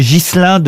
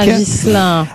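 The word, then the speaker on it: Ghiselin: Augier Gillin de Busbeck, pardon, j'ai écorché son nom. – Il me semblait bien aussi. – Ça, c'est ça, oui. Est-ce que vous pouvez appeler Busbeck? – Busbeck. Augier Gislain de Busbeck. – Ah, Gislain.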